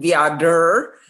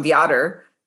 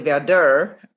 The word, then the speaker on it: Viader: His name is actually Damien Viader with the accent on the ER, right? And our announcers, because I have both the streams going, I had the Fox stream going, and then I had the OC stream going. They mispronounced his name. They call him Viader. And our guys pronounced it correctly, Viader, you know, the accent later.